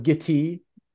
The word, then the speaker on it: Gitea: And there's a, there's another one that I'm not sure if you guys have heard of called Gitea. It's like oh no, it's called Giti.